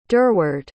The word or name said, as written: durward